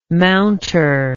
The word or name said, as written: mounter